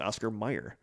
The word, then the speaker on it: Oscar Mayer: it's always been Oscar Mayer, but it's it's pronounced Oscar Mayer.